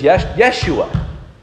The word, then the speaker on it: Yeshua: In fact, it would be pronounced in Hebrew as Yeshua.